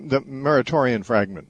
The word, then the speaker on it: Muratorian fragment: I'm wondering if you've ever heard of the, I'm going to probably butcher this word, but I think it's Muratorian fragment. – Yeah, the Muratorian fragment, yeah.